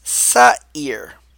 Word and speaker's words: Seir: But the Strong's number 8163 is pronounced as Seir.